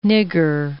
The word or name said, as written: nigger